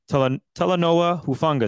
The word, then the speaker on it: Talanoa Hufanga: And that is Talanoa Hufanga.